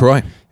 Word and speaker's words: LaCroix: LaCroix. – Is it LaCroix? – Yeah.